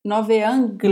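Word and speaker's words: novaeangliae: Nova Anglia, você não pronuncia a e, você pronuncia Nove, E. Por isso que eu falei Nove, Nove Angli.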